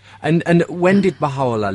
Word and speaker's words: Baha'u'llah: Yes, it's always these pronunciations are always problematic. Baha'u'llah, we say. – Baha'u'llah, okay. – Baha'u'llah.